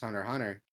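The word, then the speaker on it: Hunter x Hunter: Hunter Hunter is just pronounced Hunter Hunter. No, you don't pronounce the X.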